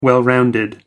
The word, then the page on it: well rounded: American English